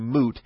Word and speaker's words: mute: He was very picky about the pronunciation of mute, and he hated the y sound in mute, and he always insisted on moot.